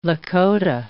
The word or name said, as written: Lakota